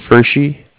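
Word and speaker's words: Pferschy: My last name's Fershi, and I'll spell it for you.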